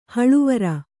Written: ಹಳವಾರ

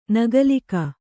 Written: ನಗಲಿಕ

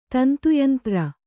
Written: ತಂತುಯಂತ್ರ

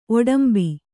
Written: ಒಡಂಬಿ

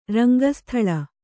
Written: ರಂಗಸ್ಥಳ

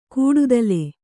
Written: ಕಡಿತಲೆ

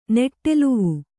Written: ನೆಟ್ಟೆಲುವು

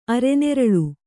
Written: ಅರೆನೆರಳು

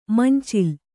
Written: ಮಂಚಲು

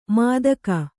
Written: ಮಾದಕ

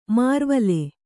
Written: ಮರವಾಳ್

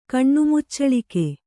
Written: ಕಣ್ಣುಮುಚ್ಚಳಿಕೆ

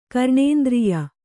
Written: ಘ್ರಾಣೇಂದ್ರಿಯ

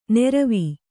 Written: ನೀರ್ವೂ

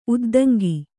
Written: ಉದ್ದಂಗಿ

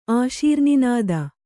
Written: ಆಶೀರ್ನಿನಾದ

